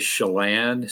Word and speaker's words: Chelan: So I hesitate to actually correct your pronunciation, but it's actually Chelan, C-H-E-L-A-N, Chelan. If you ever watched Kelsey Grammer in his TV show Frasier, they kind of assumed a different inflection and called it Chelan, just to kind of impress people, I think.